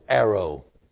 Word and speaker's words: airo: The Greek word here translated, taketh away, is spelled A-I-R-O, arrow.